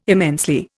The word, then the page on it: immensely: Indian